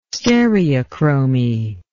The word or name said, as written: stereochromy